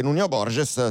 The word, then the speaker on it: Nuno Borges: Ha battuto Kecmanovic, al secondo turno affronterà il portoghese Nuno Borges, sperando, credendo, anzi reputando che questa sia la pronuncia più autentica.